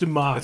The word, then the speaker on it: Dumas: but it was spelled Dumbass, but it was obviously pronounced Dumas. It's pronounced Dumas. – It's pronounced Dumas.